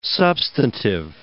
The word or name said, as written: substantive